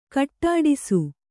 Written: ಖಡ್ಡಿಸು

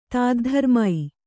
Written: ತಾದ್ಧರ್ಮೈ

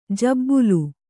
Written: ಜಿಬಿಲಿ

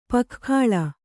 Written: ಪೊಗೆಗೊಳ್

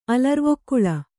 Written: ಅಲರ್ವೊಕ್ಕುಳ

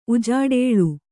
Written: ಉಜಾಡೇಳು

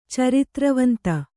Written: ಚಾರಿತ್ರವಂತ

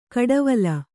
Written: ಕುಡಿವಲೆ

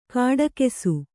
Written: ಘಟಾಕಾಶ

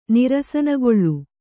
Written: ನಿರಸನಗೊಳ್ಳು

